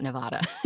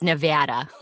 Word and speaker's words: Nevada: Yeah, I'm from the West Coast and we say Nevada. And mm-hmm. So, but I have a lot of friends from the East Coast, they say Nevada. – Nevada. – It's not Nevada. it's Nevada.